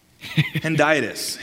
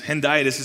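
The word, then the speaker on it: hendiadys: It's Henditis. Henditis is how it's said. Henditis.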